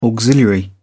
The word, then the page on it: auxiliary: British English